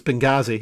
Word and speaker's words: Benghazi: And he had that one word on repeat, and it was Benghazi. He was like, this will not be a Benghazi. It will never be a Benghazi. Benghazi, Benghazi, Benghazi. It rhymes with Stasi, Benghazi.